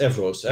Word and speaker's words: Evros: There was another, is it Evros that it's pronounced, another area? – Yes, Evros. Evros is Evros.